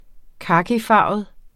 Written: kakifarvet

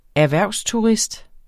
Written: erhvervsturist

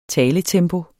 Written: taletempo

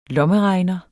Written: lommeregner

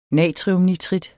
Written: natriumnitrit

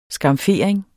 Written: skamfering

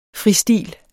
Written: fristil

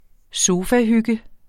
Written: sofahygge

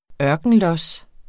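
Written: ØRKENLOS

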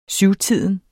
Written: syvtiden

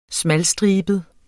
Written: smalstribet

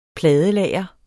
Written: pladelager

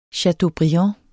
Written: CHATEAUBRIAND

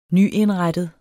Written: nyindrettet

